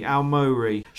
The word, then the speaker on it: Aomori: Shingo Awomori. Have I pronounced that right? Would you like to have a better feel like there's something you've got to do with the O. – Shingu. – Maybe. – Shingo Aomori. Aomori.